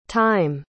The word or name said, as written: tyme